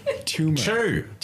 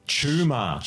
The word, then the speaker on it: Chuma: Tumor. – Chew. Americans. Can't pronounce anything. – Tumor. – C-H. Chuma.